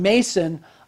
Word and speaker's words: Mnason: And notice some of the disciples from Caesarea went with him and brought with them a certain Mason of Cyprus. Now, this is a Greek name. The N, if you see it in your Bible, M-N-A-S-O-N, the N is silent.